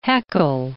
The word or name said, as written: Haeckel